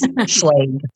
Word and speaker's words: Schlage: And I did look on their website because I kept thinking I was saying it wrong. And I'm kind of a word person. And it is Schlage.